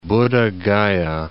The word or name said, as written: Buddha Gaya